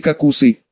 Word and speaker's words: кокусы: Требуется произнести кокусы, ударение на второй слог.